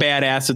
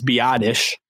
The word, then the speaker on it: Biadasz: I finally learned how to say his name. It's not badass. It's not whatever. It's Biadasz.